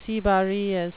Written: cibarious

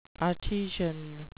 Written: artesian

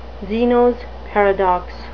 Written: zenos paradox

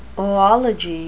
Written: oology

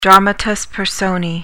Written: dramatis personae

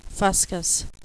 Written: fuscous

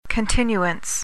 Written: continuance

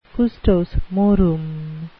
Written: custos morum

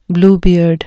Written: bluebeard

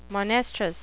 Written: monestrous

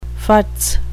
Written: futz

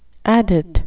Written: adit

